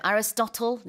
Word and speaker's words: Aristotle: Aristotle. 이거 이제 우리는 아리스토텔레스라고 yeah. 하는데 발음 많이 다르죠.